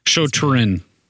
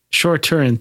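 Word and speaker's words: Shu-Torun: I can't pronounce it. – Shoturin. – Shoturin, thank you.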